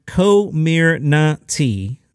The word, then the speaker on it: Comirnaty: Indeed, though the FDA announced that it's pronounced Comirnaty. See, I need the phonetics.